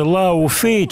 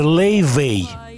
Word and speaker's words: Laufey: Пишется Лау Фей, читается Лей Вей читается по-другому. лейвей, Сразу настораживаемся.